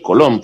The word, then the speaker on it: Colón: mejor dicho, el Colón, porque lleva acento en la segunda O, y el Morelos.